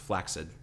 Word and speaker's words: flaccid: Yeah, the fact that I knew one night he said the term flaccid, and I said it's actually pronounced flaccid. That's the proper pronunciation.